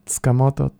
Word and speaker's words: Tsukamoto: My first name is Hiroya, H-I-R-O-Y-A. Mm-hmm. Last name is Tsukamoto, T-S-U-K-A-M-O-T-O.